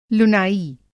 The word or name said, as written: लुनाई